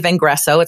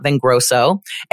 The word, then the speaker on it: Vengreso: You know how to say Vengresso. It's not Vengrosso.